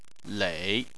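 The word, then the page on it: lei: tone 3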